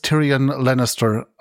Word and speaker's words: Tyrion Lannister: Es wird alles Tyrion Lannister ausgesprochen. Also ich gehe davon aus, dass es die englischen Namen sind.